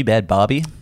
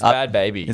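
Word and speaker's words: Bhad Bhabie: Is it Bad Baby, Bad Barbie? – It's Bad Baby. – It's Bad Baby, right? – I used to think it was Barbie.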